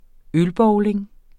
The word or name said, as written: ølbowling